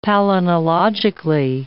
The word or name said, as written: palynologically